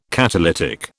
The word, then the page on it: catalytic: British English